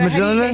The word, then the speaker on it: Mjolnir: And I was thinking to myself, well, we're going to have to give him extra time because there was the what is the hammer's name? How do you say that? – Oh, how do you... Thor's hammer. – Jolnir? – It's spelled M-J-O-L-N-I-R. – Actually, Mjolnir. – He's the geek that'll know how to pronounce that. – Mjolnir? – Is it Mjolnir? – Here we go. – Mjolnir. – Thank you. – Oh, my Mjolnir. Lord, we were so far off.